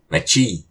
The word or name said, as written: Maci!